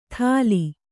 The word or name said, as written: ಥಾಲಿ